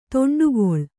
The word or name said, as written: ದಂಡೆಗೊಳ್ಳು